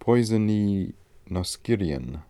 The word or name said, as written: poisoninoskirian